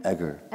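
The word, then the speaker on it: Eger: Egger.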